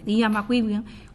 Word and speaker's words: Ian McEwan: Ian McEwan, que fue un libro prodigioso y que contiene muchas novelas.